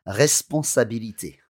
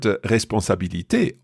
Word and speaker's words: responsabilité: But when you pronounce it, let's hear it. – Responsabilité. – To me, that sounds like an R-E acute.